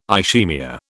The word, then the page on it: ischaemia: British English